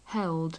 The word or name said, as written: held